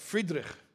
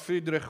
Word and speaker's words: Friedrich: Frederick, he called himself Friedrich. You'll forgive my poor German pronunciation, but in Yiddish, he's called Friedrich, which is similar to the German Friedrich.